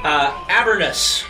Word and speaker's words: Avernus: So we have traveled deep within the Nine Hells, and we have landed in, let me get the pronunciation right, Avernus.